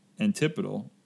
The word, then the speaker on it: antipodal: Riley reminded me that we were probably pronouncing the name Antipodal differently. So, what was antipodal? And Riley reminded me or brought up that it's actually pronounced antipodal.